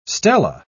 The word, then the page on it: stela: British English